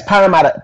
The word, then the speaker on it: Parramatta: Tell us something about St. John's Anglican Cathedral in Parramatta, and am I pronouncing Parramatta correctly? – Yes, Parramatta. So yeah, we're out here in Sydney, Australia.